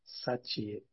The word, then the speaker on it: Satie: Satie, se escreve Satie, Satie. Henrique Satie é a pronúncia no francês.